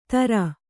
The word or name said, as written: ಥರ